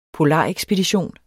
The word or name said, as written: polarekspedition